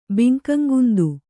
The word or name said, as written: ಬಿಂಕಂಗುಂದು